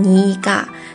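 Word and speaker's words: 네가: 再有你正好啊，你是读成你嘎。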